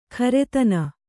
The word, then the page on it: ಖರೆತನ: kharetana